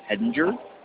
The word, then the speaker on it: Hedinger: Yes, the name is Daniel Hedinger. Last name is spelled H-E-D-I-N-G-E-R.